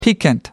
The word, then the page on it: piquant: American English